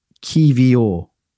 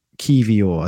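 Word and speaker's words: Kiwior: He said it's Kivior. Kivior, I think it is. So Kivior.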